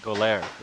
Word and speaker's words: Goler: Goler. – Yeah. – Rhymes with go there. – 11 to 4, Saturday. They're announcing Style Your Soul, S-O-L-E.